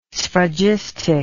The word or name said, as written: sphragistic